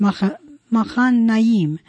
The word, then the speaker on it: Mahanaim: I mispronounced it. It's Mahanaim. Mm-hmm.